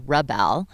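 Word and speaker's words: Rebelle: The Rebel Rally, pronounced Rebel, is a 1,200 mile off-road adventure designed specifically only for women.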